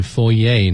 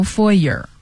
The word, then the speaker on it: foyer: 嗯，那到底是 foyer 还是 foyer 呢？都可以。 – 对了，所以美音就会把它念成 foyer，而英音呢是 foyer。